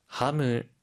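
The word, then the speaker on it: ハム: 하무, 하무. – 하무, 햄인데요. 혹시 발음 팁 있을까요? – 하이, 소세지는 시가 아니라 세로 발음해야 하고요. 하무라고 할때 끝의 소리를 무로 발음합니다.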